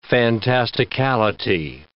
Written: fantasticality

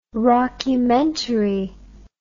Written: rockumentary

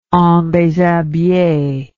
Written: endeshabille

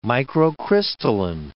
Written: microcrystalline

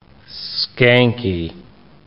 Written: skanky

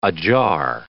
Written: ajar

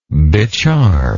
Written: becharm

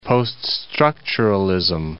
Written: post-structuralist

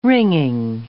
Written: wringing

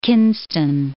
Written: Kinston

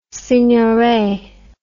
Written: signoret

